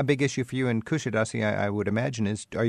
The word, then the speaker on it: Kuşadası: 2 days in Istanbul and one in Kuşadası. Is that how you say it? – Kuşadası. My hometown. – Okay. Kuşadası.